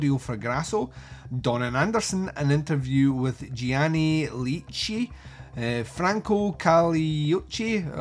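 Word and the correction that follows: Caracciolo: or no, that's not even how you pronounce that, Franco Caracoli, let's try that, The Gay Prince, original trailer, and a reversible sleeve with newly commissioned artwork by Simon Pritchard and the original Italian poster art.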